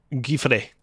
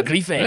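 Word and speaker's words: Griffais: Speaking to The Verge, Valve's Pierre-Louis Griffet. Did I say that right? – Hold on a sec. – Pierre-Luc? – Valve's Pierre-Luc Giffre. – I was so close. Good, yeah, Giffre.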